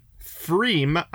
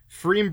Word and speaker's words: Pfriem: Now I'm going to review a Freem IPA. Freem Brewery is in Portland, Oregon. And Freem is spelled P-F-R-I-E-M.